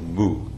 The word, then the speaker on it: Mu: Getsurin, who was famous for his severity, gave him the koan of Mu to study. Just say that the Mu is spelled M-U. It's a Japanese word. In Chinese, it's Wu, W-U.